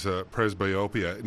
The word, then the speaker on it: presbyopia: So now, you do other studies as well, and another term that I have heard before is presbyopia.